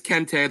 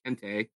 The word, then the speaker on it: Kente: Okay, we are back again for another edition of Kente Corner, a casual Hoya podcast. And it is Kente. There was some disagreement last week about how you say it. It's Kente.